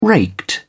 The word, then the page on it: raked: British English